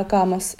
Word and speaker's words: Kaamos: Spricht man das Kaamos aus? Ist das ein finnisches Wort? – Ja, Kaamos. Ja. – Kaamos.